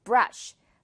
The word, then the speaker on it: brush: I'm going to go 다음에 또 바로 이렇게 brush 나왔는데 go brush 닦으러 가겠다라는 거예요. Go brush my teeth 할때 brush 아니고 brush 이 sh 발음 주의를 해야겠죠.